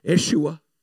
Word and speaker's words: Yeshua: If you study that word Jesus out, it was actually pronounced Yeshua.